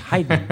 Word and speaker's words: Haydn: It's Haydn. It's not Haydn.